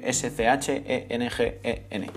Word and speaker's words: Schengen: Se escribe s c h e n g -E -N. Yo lo pronuncio Schengen.